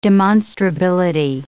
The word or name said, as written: demonstrability